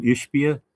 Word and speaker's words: Ishbia: And I think it's when is that how you pronounce his name, Ishbia? – Yes.